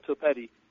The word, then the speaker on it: Topete: And again, this is Truth Quest, and tonight I've got Paul is it topete? Is that how I pronounce that, Paul? – It's like spaghetti, but it's called topete.